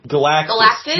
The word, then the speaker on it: Galactus: Well, isn't he the Herald of Galacticus? – Galactus? Jesus Christ. – It's only three syllables.